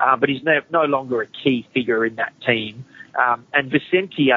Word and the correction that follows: Vicentini: I think, is that how you say it? Vicente, the ruckman? – Vicentini, yeah.